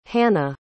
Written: hana

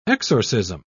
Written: exorcism

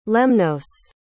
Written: lemnos

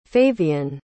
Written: favian

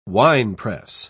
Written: winepress

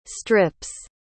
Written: strip's

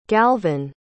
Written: galvan

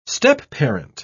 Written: stepparent

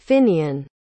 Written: finian

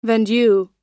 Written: vendue

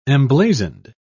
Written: emblazoned

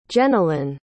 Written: jennilyn

